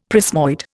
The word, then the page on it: prismoid: Indian